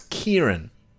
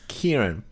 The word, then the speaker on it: Ciarán: Sorry, I just looked up how to pronounce it. You know what it is? It's a stupid fucking Irish name. It's Ciarán. – The director? – Yep. – Oh, Christ. – It's Ciarán. Fuck you, Ireland.